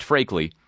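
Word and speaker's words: Freakley: Buried underground near Karbala, Iraq, according to a senior officer, Brigadier General Benjamin, it's spelled freakly and pronounced frakely, described the vehicles as dual-use, biological and chemical mobile labs.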